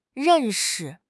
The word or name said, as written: ren4 shi5